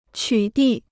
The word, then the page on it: 取缔: qǔ dì